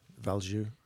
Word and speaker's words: Valjoux: And is it, how do you pronounce that? – Valjoux.